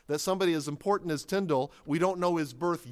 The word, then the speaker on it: Tyndale: And one of those students is our subject tonight, William Tyndale. That's the most famous portrait of him. And it is generally believed by scholars nowadays that he pronounced his name Tyndale, because sometimes it's spelled phonetically as T-I-N-D-A-L-L.